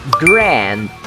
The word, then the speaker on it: grand: A quarta gíria de hoje: Grand Grand, acaba com D, tá? Mas não tem a pronúncia.